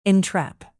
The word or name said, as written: entrap